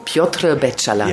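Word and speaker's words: Piotr Beczała: Ja, wir spielen jetzt mal Gern habe ich die Frauen geküsst von, wie spricht man diesen? – Piotr Beczala.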